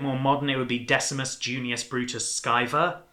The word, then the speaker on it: Decimus Junius Brutus Scaevola: It is Decimus Junius Brutus Scaevola.